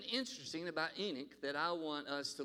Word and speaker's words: Enoch: And if you look it up in the Hebrew, it's pronounced Enoch. You have to kind of clear your voice when you say Enoch.